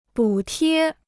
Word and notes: 补贴: bǔ tiē

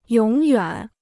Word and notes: 永远: yǒng yuǎn

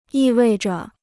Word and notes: 意味着: yì wèi zhe

